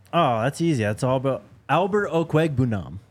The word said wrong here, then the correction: Okwegbunam: Albert Okwegbunam. – Whatever, man. – That's easy, he says, and then butchers it. – But I have like one like syllable pronounced wrong.